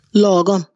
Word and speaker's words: Lagom: Lagom? – Lagom.